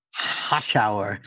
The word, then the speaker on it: Hoschouer: Hoschouer.